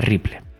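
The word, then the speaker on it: REPL: que a menudo se pronuncia de manera similar a Ripple.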